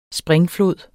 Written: springflod

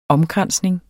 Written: omkransning

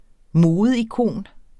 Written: modeikon

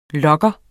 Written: lugger

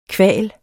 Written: kval

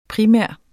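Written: primær

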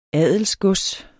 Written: adelsgods

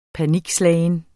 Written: panikslagen

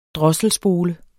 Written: drosselspole

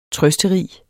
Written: trøsterig

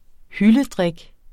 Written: hyldedrik

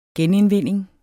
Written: genindvinding

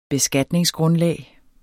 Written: beskatningsgrundlag

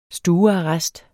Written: stuearrest

